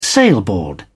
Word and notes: sailboard: British English

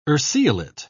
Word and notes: urceolate: British English

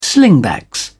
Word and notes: slingbacks: British English

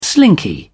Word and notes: slinky: British English

